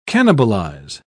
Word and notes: cannibalise: British English

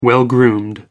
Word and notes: well-groomed: American English

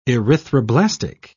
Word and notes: erythroblastic: British English

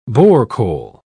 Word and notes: borecole: British English

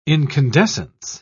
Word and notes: incandescence: British English

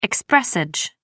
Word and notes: expressage: American English

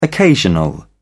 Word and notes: occasional: British English

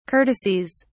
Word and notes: courtesies: British English

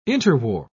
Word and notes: interwar: British English

